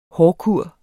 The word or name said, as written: hårkur